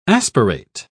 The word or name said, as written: aspirate